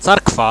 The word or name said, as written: carkva